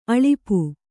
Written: ಅಳುಪ

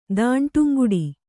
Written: ದಾಂಟುಂಗುಡಿ

